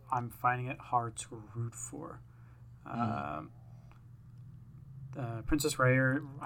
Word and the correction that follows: Rhaenyra: I can never say her name, for whatever reason. – Rhaenyra. – Princess Rhaenyra. She's often feels like she's being sidelined in her own story.